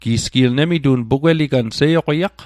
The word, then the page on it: seioqiaq: Mi'gmaq